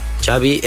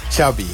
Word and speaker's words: Xavi: What is the correct pronunciation of your first and last name? – Xavi Hernandez. – Xavi. – Ja. Okay. Yeah. Xavi like chocolate.